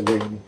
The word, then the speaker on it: Laney: por ejemplo, y repito, Laney, para los que no saben que se pronuncia Lenny que este,